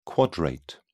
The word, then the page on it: quadrate: British English